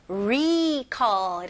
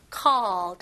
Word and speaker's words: recall: 이거는 recall, remember 하고 비슷하게 여기 recall 이렇게 힘 주시는 것보다는 그냥 에, 가벼운 I로 recall. recall 이거는 콜 아닙니다. 입술로 오 이렇게 나면 안 되고 call 턱이 내려가는 느낌입니다.